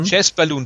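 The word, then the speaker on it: Chas. Balun: Da war ähm, eine so eine Art äh, Horrorfilm äh, Bericht drin von einem Typen, der hieß ich weiß nicht, ob ich ihn richtig ausspreche, Chas Balloon, Chas Balloon, ich. Oh, Chess Balloon, ja. Hm. Chess Balloon, danke.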